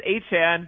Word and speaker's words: Etienne: he said, you know, it actually is Etienne, but the other way is fine too.